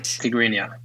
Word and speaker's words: Tigrinya: So you speak five languages, Swedish, which is your native language, English, Norwegian, Spanish, and Tigrinya. Did I pronounce it right? – Tigrinya. – Yes, good.